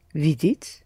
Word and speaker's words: видеть: Видеть. – Видеть. Say it again. – Видеть. – Mm-hmm. Accent the first syllable, please, again.